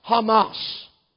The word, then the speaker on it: Hamas: Now, when you look at it in the Hebrew, it'll be spelled C-H-A-M-A-C in the English. But in Hebrew, C-H is always ha. C-H-A, ha, when it starts with it. For example, Hanukkah. Have you ever seen Hanukkah spelled with a C first? Why? Because it has the pronunciation as R-H. M-A-C is always pronounced mas, Hamas.